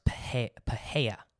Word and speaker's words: Pereira: I think it's pronounced Pahea, but I'm going to say Pereira because I'm an American and I'm ignorant.